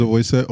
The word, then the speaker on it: Oboise: My name is Oboise, O-B-O-I-S-E, if you don't know how to spell it.